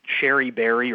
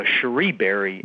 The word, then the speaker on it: Cherie Berry: Now, a lot of your listeners probably know her as Sherry Berry or mm-hmm. Cherry Berry or just the elevator lady. And that's how I would have known her till I got to know her. But you actually pronounce her name Cherie Berry, right.